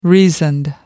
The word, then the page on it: reasoned: American English